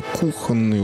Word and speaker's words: кухонные: В слове «кухонные ударения на «у»» пишет Розенталь.